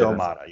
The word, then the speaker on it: Domata: It's Domata Pecco, right? Is that that's the way he pronounces it? Domata, yeah.